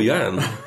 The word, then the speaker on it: Yan: You're Yan. – Not Yawn, as the Americans say.